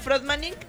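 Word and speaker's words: Fröttmaning: Como suena, Frotmanin, pero con doble T.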